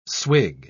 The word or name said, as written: swig